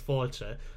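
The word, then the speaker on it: fáilte: Ben very pointedly, just before we started recording, said, can you teach me how to say the Irish for hello, which is foilche? I said, just think of foil chair.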